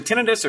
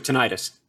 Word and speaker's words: tinnitus: Like if I have to take ibuprofen for something, all of a sudden my am I saying it right? Is it tinnitus or tinnitus? – You can say it either or either way.